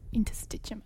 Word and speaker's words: interstitium: basically a respiratory infection deep in the lungs, in the alveoli, or like in the interst- interstitium. I cannot pronounce that word. – Interstitium. Yeah. Perfect.